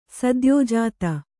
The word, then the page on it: ಸದ್ಯೋಜಾತ: sadyōjāta